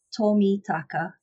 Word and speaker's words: Tomitaka: So Japanese names are easiest. As you kind of say it, is as you spell it. So Miss Tomitaka, and you'll be able to find me.